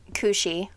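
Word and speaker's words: Cù-sìth: In all black, we have Scotland has the, I'm so pronouncing this wrong, Cusith, which is the black dog. – Is it the Cushy? – Oh, probably. Thank you.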